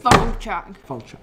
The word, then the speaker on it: Falchuk: Anthology horror television series created by Ryan Murphy and Bran Falchuk. I've never been able to pronounce his name. – Falchuk. – Right, that works.